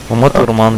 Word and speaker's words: Ума Турман: Правильно сказал, Ума Турман, да? – Ума Турман, да.